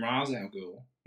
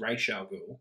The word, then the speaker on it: Ra's al Ghul: Yeah, but they might have just had trouble with pronunciations because they called him Ra's al Ghul and it's Ra's al Ghul.